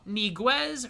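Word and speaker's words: Ñíguez: One of the bigger moves in the Premier League that finished up right before the window ended is Sal Niguez from Atletico Madrid going to Chelsea, of all teams.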